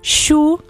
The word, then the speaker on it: Chu: Junho Xu, que é CHU. Procura aí no Instagram. – Xu de churrasco.